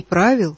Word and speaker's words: правил: Правил? Не правил? – Да, правил именно. Здесь ударение поставлено.